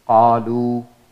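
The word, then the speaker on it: qalu (قالوا): For example, qalu, qalu. This alif is silent. We do not pronounce the alif.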